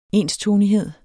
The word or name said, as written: enstonighed